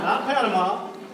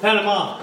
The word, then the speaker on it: Panama: Not Panama. Panama. – Panama. – Just want to make sure you know that. I learned that. I was wondering why everyone in that country was pronouncing their name wrong.